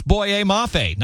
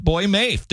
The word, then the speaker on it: Boye Mafe: At 4 o'clock, I accidentally butchered the name of new Bengals edge rusher Boye Mafe. For the record, it's Boye Mafe, not Boy Mafe.